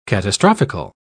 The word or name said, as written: catastrophically